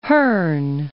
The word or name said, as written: Hearn